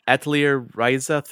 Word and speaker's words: Atelier Ryza: Atelier Ryza 3, is that how I pronounce that? – Yep.